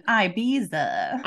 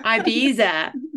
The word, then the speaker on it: Ibiza: Yes, you got to say it correctly, right? – Exactly. – You can't be all American. Ibiza. – Ibiza, right?